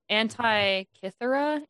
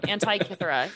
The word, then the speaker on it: Antikythera: So it's the Antikythera. – Right. Okay. – Yeah, I'm even looking at the pronunciation of it. Antikythera.